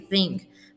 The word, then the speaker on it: thing: You know uh, that pronunciation is my thing, and notice how I say thing with a pop in my G sound at the end because it ends with an ng. So you don't really release the G here, think.